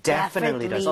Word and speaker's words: definitely: 자, 그런데 강세를 여러분들 이쪽에 줘보는 거예요. 이렇게 하게 되면, definitely, 이렇게 앞쪽에 강세가 가면서 오. – 뒤에 틀리라고 할 여유가 안 생기게 돼요. – 네. – 그렇게 돼서, definitely, 이런 이상한 발음이 아니라, definitely, definitely. 이렇게 발음이 될 수밖에 없습니다.